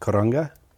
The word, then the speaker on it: karanga: And this year you got a specific theme of karanga. Am I saying that right? – Yeah.